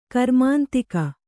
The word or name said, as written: ಗ್ರಾಮಾಂತಿಕ